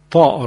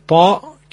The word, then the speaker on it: ط: حرف طا تلفظش اینن مثل حرف تی دو نقطه هست، با این تفاوت که صداش درشت و پرحجمه.